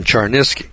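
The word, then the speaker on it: Cherniske: His name is Stephen, that's spelled S-T-E-P-H-E-N, Char, C-H-E-R, Niske, N-I-S-K-E. Stephen Charnisky.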